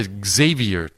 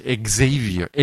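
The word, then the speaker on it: Xavier: そしてこれはその日本と比べができるんじゃないかなと思うんですけれども、 560 年前、ザビエっていう宣教師が日本に来て、ザビエ発音間違えてるなら、ザビエルか。英語でグゼビアって言うんですけど、フランシス・エグゼビア、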